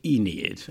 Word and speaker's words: Aeneid: Okay, well, first of all, let's pronounce it right. In English, it's the Aeneid.